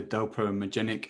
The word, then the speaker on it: dopaminergic: I'm just curious about maybe maybe um, maybe you've already explained this in the sense by introducing the dopaminergic.